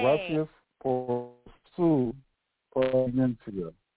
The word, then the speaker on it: paciencia: The I-E and the I-A at the end of paciencia, that last word, are pronounced separately. So the vowels in Spanish, when they're next uh-huh. – to each other, are pronounced separado, separately, okay?